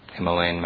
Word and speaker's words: Himalaya: And of immovable things, I am north of India, around the Nepal area, Himalayas, Himalayan mountains. What's the correct pronunciation of Himalaya? – Himalaya. – Hima. Alaya. Hima and Alaya together becomes Himalaya.